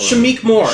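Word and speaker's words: Shameik Moore: Shamik Moore.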